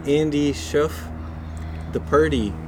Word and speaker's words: Shauf: Yeah, it's spelled S H A U F.